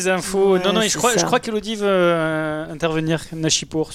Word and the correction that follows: Nishapur: sur Nashipur. Non, tu faisais pas un geste, pardon. – Nishapur, pardon, excusez-moi. – Nishapur.